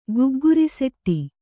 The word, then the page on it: ಗುಗ್ಗುರಿಸೆಟ್ಟಿ: gugguri setty